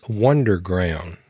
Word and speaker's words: Wunderground: And that is Wonderground. Now, it's spelled WUNDERGROUND, Wonderground with a U instead of an O.